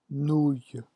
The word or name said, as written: nouille